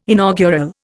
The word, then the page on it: inaugural: Indian